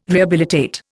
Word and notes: rehabilitate: Indian

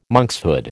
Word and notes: monkshood: American English

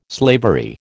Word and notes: slavery: American English

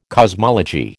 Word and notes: cosmology: American English

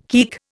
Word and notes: keek: Indian

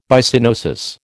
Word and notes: Byssinosis: American English

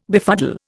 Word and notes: befuddle: Indian

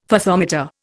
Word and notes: Fathometer: Indian

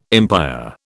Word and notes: empire: British English

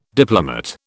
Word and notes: diplomate: British English

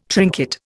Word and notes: trinket: Indian

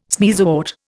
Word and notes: sneezewort: Indian

